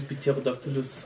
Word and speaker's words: Pterodactylus: Pterodactylus Pterodactylus, Pterodactylus. – Und ähm, ja, The P is silent, weil ja auch niemand das richtig weiß, weil es damals halt einfach.